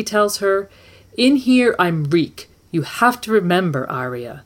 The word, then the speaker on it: Reek: Theon Greyjoy might have tried to help her once, but Theon had been ironborn, and a braver man than Reek. Reek, Reek. It rhymes with weak.